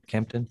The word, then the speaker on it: Campden: That's C-A-M-P-D-E-N. I might be mispronouncing it. Maybe Campden? Campden? Tablets. – Campden. Okay.